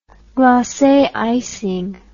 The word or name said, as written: glace icing